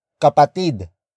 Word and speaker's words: kapatid: The next word is kapatid. Kapatid. Kapatid. Kapatid is spelled as K-A-P-A-T-I-D.